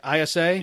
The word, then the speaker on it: ISA: Am I saying that right? ISA? – Yeah.